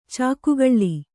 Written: ಚಕ್ಕೆಗಲ್ಲು

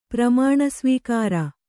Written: ಪ್ರಮಾಣಸ್ವೀಕಾರ

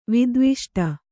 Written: ವಿದ್ವಿಷ್ಟ

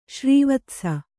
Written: ಶ್ರೀವತ್ಸ

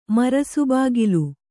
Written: ಮರಸುಬಾಗಿಲು

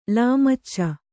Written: ಲಾಮಚ

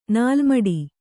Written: ನಾಲ್ಮಡಿ

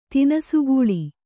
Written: ತಿನಸುಗೂಳಿ

